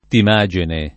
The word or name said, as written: Timagene